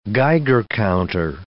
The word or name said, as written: Geiger counter